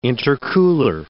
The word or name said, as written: intercooler